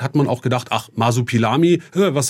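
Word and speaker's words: Marsupilami: Wolfgang Kotzian aus Blaustein möchte wissen, warum es eigentlich zu der Umbenennung kam von Kokomikos in Masopilami. Und ich habe es wahrscheinlich schon falsch ausgesprochen. – Nee, es ist im Großen und Ganzen richtig. Also das Masopilami, also Masopilami ist auch der französische Name.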